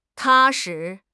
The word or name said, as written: 踏实